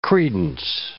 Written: credence

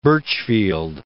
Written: Burchfield

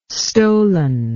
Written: stollen

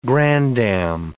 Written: grandam

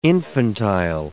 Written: infantile

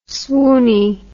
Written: swoony

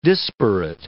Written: disparate

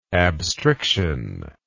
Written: abstriction

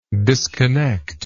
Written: disconnectiveness

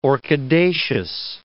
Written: orchidaceous

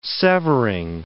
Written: severing

